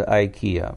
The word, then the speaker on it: IKEA: Ну, по-английски это читается IKEA. Первая буква I в открытом слоге IKEA читается.